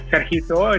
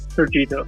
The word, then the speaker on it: Sergito: Spanish is Sergito, in English is Sergito.